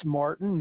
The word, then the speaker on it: Martin: Nicholas Martin.